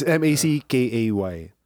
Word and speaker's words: Mackay: It's Mackay.